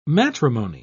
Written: matrimony

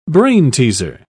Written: brainteaser

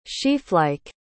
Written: sheaflike

